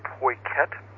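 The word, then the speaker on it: Peukert: I think the pronunciation is Poiket.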